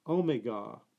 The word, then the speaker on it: Omega: For language purists, the English pronounce Omega with a short E, putting the emphasis on the final vowel, so it's Omega.